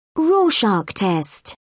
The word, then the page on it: rorschach test: British English